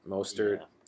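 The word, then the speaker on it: Mostert: Um, Raheem, Ma, I never know how to pronounce his last. Mustard. Mostard. Mostard. Yeah.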